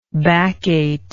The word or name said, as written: baccate